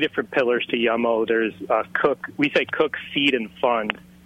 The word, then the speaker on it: Yum-O: Yum-O, yeah.